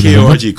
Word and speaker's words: Korg: KORG, Korg. Yeah. Okay. – And pronounce it properly. – Oh, so cool. Yeah, I got to pronounce it properly. Yes. There's a G at the end.